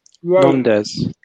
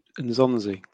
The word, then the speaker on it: Nzonzi: Amin, Blackburn, Stoke, Sevilla, Rondes. – Pardon? – Nzonzi.